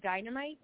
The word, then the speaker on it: Dynamyte: So my Instagram and my TikTok are Call Me Dynamyte, which is spelled a little differently. The I is a Y, so D-Y-N-A-M-Y-T-E.